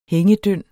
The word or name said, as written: hængedynd